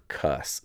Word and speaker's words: Cuss: Speaking of knowing what's yours versus theirs or someone else's, I just finished a book written by a man named Steve Cuss, so spelled like the cuss word, C-U-S-S.